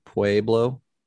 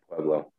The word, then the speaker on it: Pueblo: I thought you just went to Colorado Pueblo. How do you say it? – Pueblo.